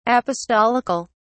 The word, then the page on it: apostolical: British English